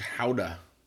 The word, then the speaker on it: Gouda: But I'm eating some aged howdah. – Wow, you're going to go ahead and pronounce it like howdah. – That, are you? – Mm-hmm. Yeah, this is Gouda, which has calcium lactate in it.